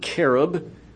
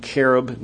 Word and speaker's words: cherub: And he says, you are the anointed cherub. Notice the pronunciation, cherub, not cherub.